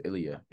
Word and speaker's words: Ilya: But Ila, is that how you say it, right? – I think it's Ilya. – Ilya, yeah. Ilya.